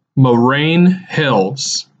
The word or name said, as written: Moraine Hills